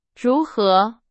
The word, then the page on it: 如何: Chinese